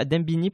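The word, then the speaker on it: Dembéni: Concernant les, les, les, mouvements sociaux, euh, toi, par exemple, tu es à Dembéné, c'est ça, Dembini, pardon qu'est-ce qui ça, ça se prononce comme ça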